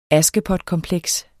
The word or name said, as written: askepotkompleks